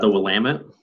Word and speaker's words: Willamette: He also, have you been on Wilmette River? Wilmette? That rings a bell. – The Willamette. – Willamette. Oh man, I butchered that.